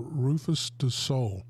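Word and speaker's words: Rüfüs Du Sol: It's called Rufus Du Sol, is the name of the group from Australia.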